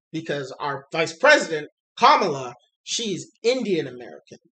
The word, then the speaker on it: Kamala: Yeah, no, this one's definitely going to cause a lot of confusion, but yes, her comics accurate pronunciation is Kamala.